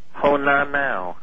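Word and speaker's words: Honaunau: H-O-N... – A-U-N-A-U. – N-A-U. That's a weird name. And you pronounce that... – Honanau.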